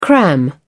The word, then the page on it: cram: British English